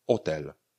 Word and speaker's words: hotel: Třeba slovo hotel. Píšeme hotel, ale vyslovujeme hotel hotel Nebo slovo marihuana.